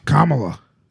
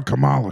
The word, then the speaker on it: Kamala: Kamala. Don't call her Kamala. She doesn't like it. – I didn't mean that. – Don't you dare pronounce it one syllable off.